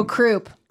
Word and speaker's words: croup: I don't know how to say this word, but crop said. – Oh, croup.